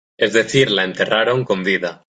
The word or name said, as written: decir